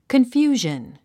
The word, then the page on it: confusion: kənfjúːʒən; コンフュージャン